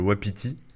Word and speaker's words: WAPT: Il y en a un, on va voir si on en parle, mais qui s'appelle Wapiti. Alors ça s'écrit W-A-P-T, qui est en fait un, pareil, un gestionnaire de paquets qui est plus destiné au.